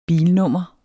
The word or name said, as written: bilnummer